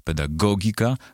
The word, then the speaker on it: pedagogika: Jak pisze PWN, w starannej wymowie wymienione wyrazy należy akcentować na trzeciej sylabie od końca. Zatem matematyka, fizyka, pedagogika, muzyka.